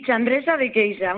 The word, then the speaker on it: Chandrexa de Queixa: ¿Se dice así? ¿Chandrexa de Queixa? – Sí, Chandrexa de Queixa. Muy bien.